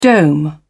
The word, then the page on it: dome: British English